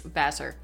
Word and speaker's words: Vassar: Yes, I say it correctly? – Vassar. – Vassar. God damn it. – No, you're technically correct. – Technically. – Because it's French, and the original version is Le Vassar.